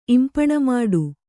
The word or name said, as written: ಇಂಪಣಮಾಡು